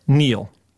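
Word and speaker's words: kneel: And Neil. – Okay. – The K, which was silent until I spelled it earlier in the clip.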